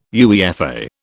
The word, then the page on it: UEFA: British English